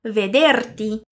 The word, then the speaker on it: vederti: E agora prestando atenção na pronúncia do T e do I juntos, que não fica ti, mas sim ti. Vamos lá. Vederti. Vederti. Benissimo!